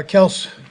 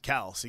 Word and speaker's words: Kelce: I do appreciate that Andy Reid uses the proper pronunciation of Travis Kelce. – Kels, hyperextension, we'll see what happens. – Is that one of the old – still, it's one of my favorite moments. Travis Kelce's like, my name's Kels. You guys have been pronouncing it wrong.